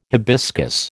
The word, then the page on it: hibiscus: American English